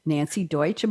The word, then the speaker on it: Nanci Deutsch: We've got, oh my goodness, we have got Nanci Deutsch.